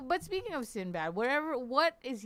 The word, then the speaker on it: Sinbad: Am I saying it right? Sinbad? – Sinbad, yes.